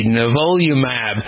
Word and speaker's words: Nivolumab: It's N as in Nancy, I-V-O-L-U-M-A-B, Nivolumab, N-I-V-O-L-U-M-A-B.